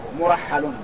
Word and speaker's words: مرحل: قالت مرحل، قولها مرحل كما تسمعونه هذا نطقه مرحل بضم الميم وراء مهملة مفتوحة وحاء مهملة مشددة، هكذا مرحل كمعظم.